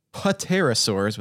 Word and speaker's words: pterosaurs: So today we are talking about pterosaurs, and that's spelled like pterosaurs with that silent P at the beginning.